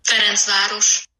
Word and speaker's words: Ferencváros: I'm calling them FTC here because I don't know how to actually pronounce the real name, but apparently it's supposed to be like this. – Ferencváros.